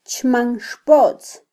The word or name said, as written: chmanxpotz